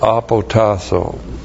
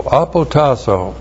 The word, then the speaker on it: apotasso: And then the verb apotasso. A-P-O-T-A-S-S, long O. Apotasso.